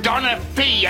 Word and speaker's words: Donaghy: It's pronounced Donna Fee, a lace curtain half an Englishman.